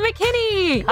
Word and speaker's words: McKinney: And today we have another special guest NPC, Heather McKinney.